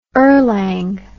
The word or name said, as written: erlang